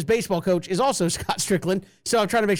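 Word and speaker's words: Stricklin: I did see where, was it Scott Strickland? Am I saying that right? He's the AD at Florida, right? – Yes.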